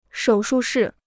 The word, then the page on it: 手术室: shǒu shù shì